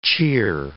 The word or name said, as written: cheer